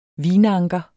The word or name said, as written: vinanker